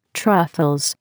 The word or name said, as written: truffles